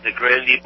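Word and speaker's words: Grande: You recorded at Detroit's famed, is it pronounced the Grandy Ballroom or Grand Ballroom? And the Grandy Ballroom.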